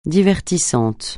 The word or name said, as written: divertissant